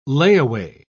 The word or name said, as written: layaway